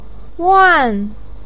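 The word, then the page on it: wan: tone 4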